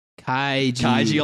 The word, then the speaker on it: Kaiji: It's not Kenji, you're saying that wrong. – It's Kaiji? – Kaiji Ultimate Survivor? Okay, yes.